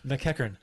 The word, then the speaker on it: McEachern: How did you say that? – Mackenzie McEachern. McEachern. Because that's the way it's pronounced and spelled.